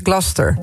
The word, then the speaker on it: Gloucester: De Engelse plaatsnaam Gloucester spreek je uit als... – Gloucester. – Gloucester. Ja. Als één vloeiend woord. Gloucester.